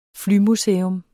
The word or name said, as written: flymuseum